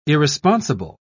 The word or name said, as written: irresponsible